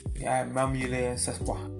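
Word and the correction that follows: sòs pwa: Cespois. I'm saying it right? – No. Sauce pois.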